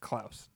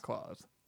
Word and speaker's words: Klaus: And that is how you pronounce it. Klaus. – Klaus. – Not Klaus.